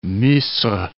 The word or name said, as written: Misr